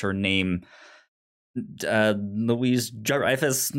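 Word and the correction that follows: Julia Louis-Dreyfus: however you say it. – Yeah, Julia Louis Dreyfus.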